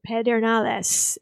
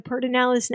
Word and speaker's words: Pedernales: Also, a quick okay, Spanish speakers, I know you're going to get after me. It is spelled Pedernales. It is. And if you were saying it properly, that's what you would do. But Lyndon Johnson always called it Pedernales. That's from the area where he grew up, and that's how he said it, and that's how half of them say it now at the Pedernales National Park.